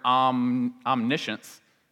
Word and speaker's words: omniscience: And if you look at the word omniscience, it's really just omniscience is what it actually says, but we just pronounce it shunts instead of science.